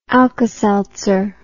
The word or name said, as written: alkaseltzer